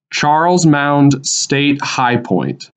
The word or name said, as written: Charles Mound State High Point